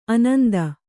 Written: ಆನಂದ

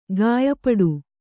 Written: ಕಯಿಪಾಡಿ

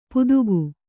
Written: ಪುದುಗು

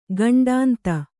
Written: ಖಂಡೇಂದು

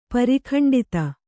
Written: ಪರಿಖಂಡಿತ

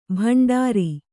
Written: ಭಂಡಾರಿ

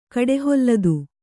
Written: ಕಡೆಹೊಲ್ಲದು

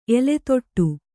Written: ಎಲೆತೋಟ

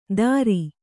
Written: ತರಿ

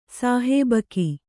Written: ಸಹಭಾಗಿ

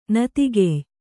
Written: ನತಿಗೆಯ್

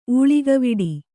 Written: ಊಳಿಗವಿಡಿ

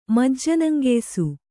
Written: ಮಜ್ಜನಂಗೆಯ್ಸು

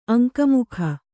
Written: ಅಂಕಮುಖ

